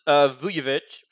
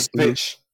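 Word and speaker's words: Vučević: Zach LaVine, DeMar DeRozan, Nikola Vucevic, I'm not pronouncing that correctly. – Vucevic. – There you go.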